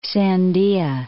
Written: Sandia Mountains

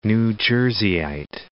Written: New Jerseyite

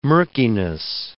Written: murkiness

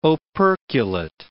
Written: operculate

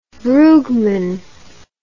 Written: brugman

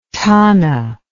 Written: thana

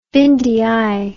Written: bindieye

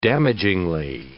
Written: damagingly

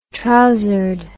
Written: trousered

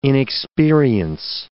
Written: inexperience

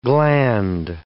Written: gland

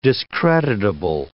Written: discreditable

